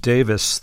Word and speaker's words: Davies: It's very common in Wales, and I think it's pronounced Davis there.